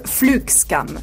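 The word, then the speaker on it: flygskam: C'est un mot qui s'écrit flixcam avec un Y, mais se prononce flugscam.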